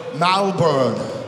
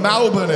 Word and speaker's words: Melbourne: Melbourne, or if you live in this city, you affectionately call it Malbourne. I don't know whether Mal is in it, but let's call it. Melbourne is how you say it, right? I've been saying it wrong for ages because I'm from Sydney.